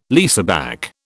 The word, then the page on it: leaseback: British English